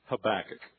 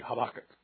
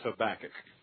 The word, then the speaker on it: Habakkuk: It's on page 1241, Habakkuk. That's the American pronunciation of it. The way the Hebrews would say it would be Habakkuk, real fast like that. So I'm going to slow down and use the Texas pronunciation, which is Habakkuk.